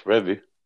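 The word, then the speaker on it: Revie: So we'll get, my quote that I'm going to pull out tonight is from Don Reavy, and he was talking about the winger Eddie Gray. – You better say his name right. – Did I say it wrong? – I think you'll find it's Revy.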